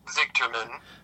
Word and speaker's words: Zigterman: Close enough. It's Zigterman.